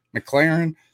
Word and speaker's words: McLaren: Yeah, McLaren.